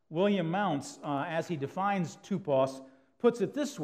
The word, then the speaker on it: tupos: I know it looks like typos up there, but it's actually pronounced tupos.